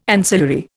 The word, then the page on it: ancillary: Indian